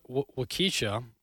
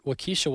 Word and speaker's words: Waukesha: Um, this uh, back to this Wak- Waukesha. I don't even know how to pronounce that. I hope I'm getting that right. Waukesha, Wisconsin.